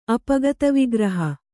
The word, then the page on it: ಅಪಗತವಿಗ್ರಹೆ: apagatavigrahe